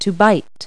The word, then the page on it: bite: verb